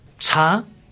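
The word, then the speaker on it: cha (茶): Bueno, cha es el té pronunciado en chino mandarín.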